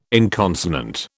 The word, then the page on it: inconsonant: British English